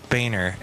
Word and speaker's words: Boehner: Boehner. It's pronounced Boehner.